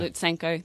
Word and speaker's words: Lutsenko: Astana as well uh, with um, Lopez, and I always pronounce his name Lutsenko. wrong again. – Lutsenko, thank you.